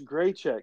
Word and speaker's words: Grichuk: They picked up CJ Cron and Randall Gerchuk from the Colorado. Great check. Okay. – Yep. Czechoslovakian. – That's pronounced Grichuk. Grichuk is Grichuk.